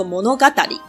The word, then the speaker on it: 物語: 那物语呢，在日文里面是故事的意思，它念作 monogatari。